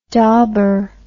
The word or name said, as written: dauber